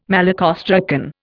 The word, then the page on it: malacostracan: Indian